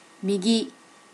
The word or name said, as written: migi